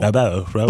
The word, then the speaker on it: Rabot: Can I take a guess here that this is a French pronounced last name? I think this might be Dallas Rabot. – Rabot. – But it's right. R-A-B-O-T.